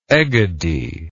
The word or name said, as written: egadi